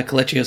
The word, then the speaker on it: Kelechi: Uh, Kelechi Osemele.